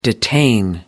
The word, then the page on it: detain: American English